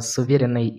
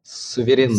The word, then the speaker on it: суверенный: Это суверенный интернет. Всерьез? О а суверенном интернете? – Суверенный. – Суверенный, точно. Я думаю, где не так... Надо было ударение поставить в тексте.